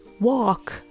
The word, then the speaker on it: walk: 続いて、 walk の L は発音しません。